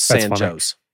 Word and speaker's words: San Jose: There was a huge Hispanic influx into that area that helped create this town, but they wanted to put it with a, they made them pronounce it with an English pronunciation. So it's San Jose.